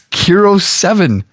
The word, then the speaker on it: KIRO 7: K-I-R-O-7. – That's what it is. Not Kiro. It's just K. That's the... Oh, my God. You're reading the TV code as a word. – It's Kiro7. – No, it's K-I-R-O-7.